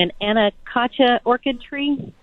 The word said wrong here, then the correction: Anacacho: You came very close. Anacacho orchid tree. It's easier to say in Bauhinia congestus, so we'll stick with Anacacho.